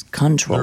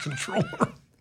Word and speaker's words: comptroller: Are they a comptroller or a controller? – It's controller. – They're a comptroller. – It's misspelled. – It's pronounced controller.